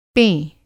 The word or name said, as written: पें